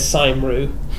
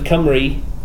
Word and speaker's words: Cymru: Because their speech says Saimru instead of Cymru, and there are lots of other words like this where Cymraeg.